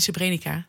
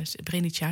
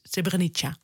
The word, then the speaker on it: Srebrenica: En dat was, ja, zo moet je het uitspreken. – Het is niet Srebrenica, het is uh, Srebrenica, het is Srebrenica.